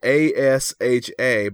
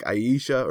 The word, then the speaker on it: Asha: Uh, but her first name is spelled A S H A, but I'm I know it's not pronounced Asha. I think it's always been like Aisha or something like that.